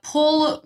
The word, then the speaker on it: Poul: Paul Paul would be the first name, like literally how it's spelled.